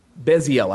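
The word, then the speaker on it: Bezalel: Um, don't quote me on that, but Beziel. I think that's how you pronounce his name. Is that right? Yeah. Okay.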